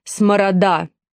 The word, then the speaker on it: Сморода: Перед Катей стояла клиентка по фамилии Сморода, с ударением на последний гласный.